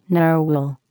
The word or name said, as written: narwhal